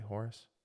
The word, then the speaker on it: Horus: Did I say that right, Horus? – Yes.